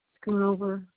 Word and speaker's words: Schoonover: Scoot over. – Can you spell that for me? – S-C-H-O-O-N-O-V-E-R.